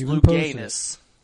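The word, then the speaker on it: Louganis: Well, I know there's one famous swimmer that turned 61 today, Greg Lucanus. Today is his birthday. It is. He's 61 today. – That's Luganus. That's Luganus, Mark.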